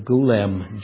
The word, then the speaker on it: golem: If I pronounced it correctly, it's the word golem, G-O-L-E-M.